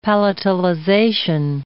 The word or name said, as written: palatalization